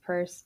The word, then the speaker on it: Pierce: It looks like Pierce, but it's pronounced Purse.